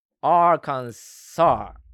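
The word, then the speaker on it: Arkansas: 俺ね、フランス語のことはよくわからないんだけれども、最後の S は無音、サイレンス、発音しないということで、アーカンサーってことで、最後、サースとはならない。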